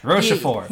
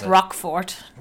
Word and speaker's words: Rochefort: But anyway, yeah, Young Girls of Rochefort, I learned how to pronounce that town while watching the movie. Rochefort. – You just couldn't figure it out. – Well, you know, I want to Americanize it. Looks like Rockfort to me.